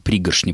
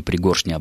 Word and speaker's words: пригоршня: Слово «пригоршня», «пригоршня» образовано от слова «горсть», а вот ударение может быть и тем, и иным, оба считаются нормативным.